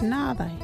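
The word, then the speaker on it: nhaathayi: local Wadjuri word is nāthai.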